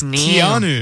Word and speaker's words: Keanu: That's not it. It's Keanu.